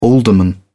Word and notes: alderman: British English

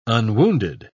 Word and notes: unwounded: British English

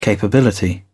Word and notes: capability: British English

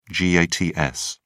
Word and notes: gats: British English

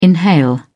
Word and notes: inhale: British English

